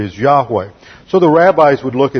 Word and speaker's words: Yahweh: See the yod and the H, Y-H-W-H. This is called the sacred tetragrammaton, or four letters, the sacred name of God, which is usually pronounced Yahweh. And at one time, the Old English, they transliterated the Y with a J, and they inserted the vowel points from Adonai, and it became Jehovah.